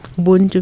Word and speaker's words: Ubuntu: Ubuntu? – Ubuntu, mm-hmm.